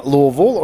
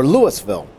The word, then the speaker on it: Louisville: You know, if you're from Louisville or know of Louisville, many people will pronounce Louisville Louisville or Louisville. That's not how the people in Louisville say it. They say it Louisville, almost like they got a couple marbles in their mouth.